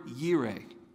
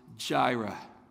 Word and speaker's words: Yireh: The word tend is the word yire. You might be more familiar with hearing it pronounced Jireh. The Lord who provides, Jehovah Jireh. It's the same thing, Yahweh Yireh.